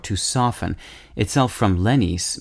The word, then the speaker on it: lenis: Linguists also borrowed lenis, pronounced lenis in English, L-E-N-I-S, to describe speech sounds that are softened. For instance, the T sound in gutter, G-U-T-T-E-R, is lenis.